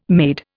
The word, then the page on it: maid: Indian